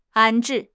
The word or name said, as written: 安置